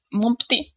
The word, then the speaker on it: Monpti: Und der wird M-O-N-P-T-I geschrieben und Mumpti ausgesprochen, hoffe ich.